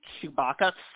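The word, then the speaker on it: Tshibaka: It's pronounced Kelly Chewbacca. So it's very close to the Star Wars character.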